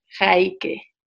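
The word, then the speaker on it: Heike: Y la manera correcta de pronunciarlo es Jaike.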